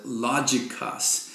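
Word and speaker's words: logikos (λογικός): The word that's translated spiritual in the translation I'm using is the Greek word logikos. And you can see just even in the, what you might guess at how to pronounce it, logikos.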